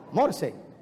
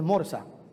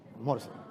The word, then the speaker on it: Morse: Morse, que no es Morsa, Morse. La clave Morse. La primera frase que se escribió en clave Morse fue números 2323.